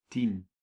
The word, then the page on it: ten: /ˈtɛn/; [tʰɛn]